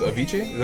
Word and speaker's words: Avicii: SOS with Avicii. Is that how you say it? – Yeah.